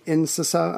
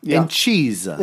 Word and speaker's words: Incisa: Incisa, I-N-C-I-S-A, Incisa. I'm not sure how to say it, but I would have gone Incisa. – Incisa, thank you. That's what I needed.